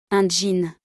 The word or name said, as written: Un jean